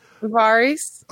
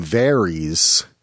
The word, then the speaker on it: Varys: Varys. – Okay. I called him Varys. – A lot of people do.